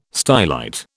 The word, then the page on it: stylite: British English